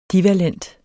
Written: divalent

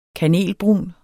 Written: kanelbrun

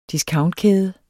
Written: discountkæde

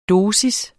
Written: dosis

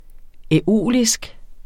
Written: æolisk

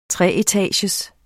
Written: treetagers

